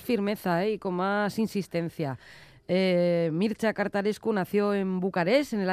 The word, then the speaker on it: Mircea Cărtărescu: Bueno, ¿quieres adentrar en el mundo de, a ver si lo digo bien, Mircea Cărtărescu? – Sí.